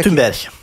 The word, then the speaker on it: Thunberg: De ugye Greta Thunberg, ugye mi a helyes kiejtése? Kérlek segítsek. – Thunberg.